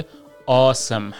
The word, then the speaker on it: awesome: این awesome هم تلفظ نمیشه ها، چون خیلی اون رو سام تلفظ میکنن مثل کلمه سام، ولی وقتی که میاد در ابتدا یعنی در انتهای کلمه آ که awe میشه، میشه awesome. مثل handsome، handsome هم داریم.